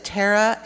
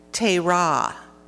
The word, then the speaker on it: Terah: And some pronounce it Tara, and other pronounce it Tera, as being the leader or the patriarch. And it's spelled T-E-R-A-H, for those who are not able to read that in your Bibles.